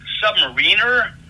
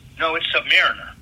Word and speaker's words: Submariner: Submariner? Because I thought that's how you should pronounce it, because I find out later it's Submariner. So more like the rhyme of the Ancient Mariner than the atomic submarines.